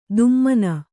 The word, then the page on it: ದುಮ್ಮನ: dummana